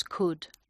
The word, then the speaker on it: could: Sí, pues es igual, esta es igual que walk, ¿no?, porque hay una L que no se pronuncia. Entonces, could.